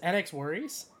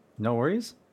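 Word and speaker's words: NxWorries: Is NX Worries? – No Worries? – No Worries.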